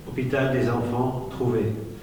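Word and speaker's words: Hôpital des Enfants-Trouvés: All five of his children were unnamed and placed immediately after birth in the Hospital des, I'm going to butcher this, Enfants, E-N-F-A-N-T-S, T-R-O-U-V-E-S. – Hôpital des enfants trouvés.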